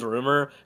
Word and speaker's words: RMR: His name's Rumor. It's spelled RMR in all caps.